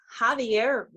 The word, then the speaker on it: Javier: But it's Javier Reyes did impress me. Mm-hmm. Yeah. – Oh, so we were saying Xavier Reyes. Were we saying it wrong? – Yes.